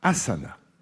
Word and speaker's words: asana: D'A'ASANAS, mais on prononce asana. Je rappelle la question. Dans quelle discipline parle-t-on d'asana? Asana.